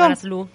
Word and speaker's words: Manaslu: Manaslu.